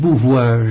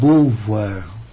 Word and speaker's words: Beauvoir: No es Beauvoir, es Beauvoir.